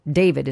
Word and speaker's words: D4VD: David, as he's known, D4VD is how he spells it. David is how he pronounces it.